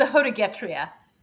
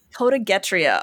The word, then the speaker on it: Hodegetria: And again, I mentioned before that I tend to think of myself as a bit of a history nerd, but I'm not even sure I know how to pronounce this one. It's the Hodegetria? – It's the Hodegetria. – Hodegetria. All right.